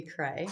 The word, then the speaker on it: Crea: First, I'm going to pronounce my last name for you. It's Lindy Cray. Cray.